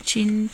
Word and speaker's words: Cin: Tietje, ich weiß nicht, Tite, vorne ist Tietje, aber das war Tietje Chin, glaube ich. – Tietje Chin mit C-I-N geschrieben.